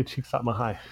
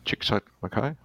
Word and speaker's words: Csikszentmihalyi: Csikszentmihalyi. – Yeah, Csikszentmihalyi.